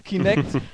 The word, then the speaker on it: Kinect: Unter anderem natürlich das ehemalige Projekt Natal, was jetzt Kinetic heißt. – Falsch, nee. Du hast das schon falsch gesagt. – Das habe ich falsch gesagt. – Kinect. Kinect, verdammt.